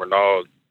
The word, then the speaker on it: Renald: My name is Ronald. I'm definitely a new caller. – Ronald. How you spell that? – R-E-N-A-L-D. It's spelled like Reynolds, but it's pronounced Ronald.